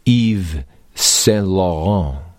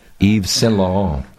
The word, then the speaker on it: Yves Saint Laurent: Now, of course, it's not so unusual because we've all heard of the designer Yves Saint Laurent. Did I say his name right? – Oh, you said it beautifully. – Not bad, huh? Yves Saint Laurent.